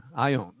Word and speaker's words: aion: You say it's spelled A-I-O-N in the Greek, or it's defined as long I, long O, I own, okay?